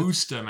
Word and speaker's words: Worcester: It's Woosta, Massachusetts. – Woosta. Oh, okay. So I said it wrong because apparently you can't just fucking pronounce it the way it's spelled.